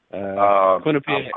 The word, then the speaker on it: Quinnipiac: How do you pronounce that? Help me out here. – Quinnipiac. – I believe Quinnipiac.